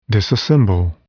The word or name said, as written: disassemble